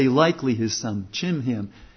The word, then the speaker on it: Chimham: But here's your servant Chimham. Now some people pronounce it Kimham. Chimham, let him go over with my lord the king and do whatever seems good to you.